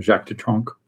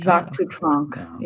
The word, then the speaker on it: Jacques Dutronc: Jacques Dutronc. – Oh my God. – Jacques Dutronc, yeah.